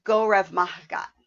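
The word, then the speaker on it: go raibh maith agat: Finally, the proper way to say thank you is go rev mahgat.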